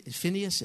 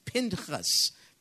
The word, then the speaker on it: Pinchas: The two sons of Eli, Hophni and see it says fin, Phineas. It's Pinchas, Pinchas in uh, Hebrew.